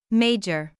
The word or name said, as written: major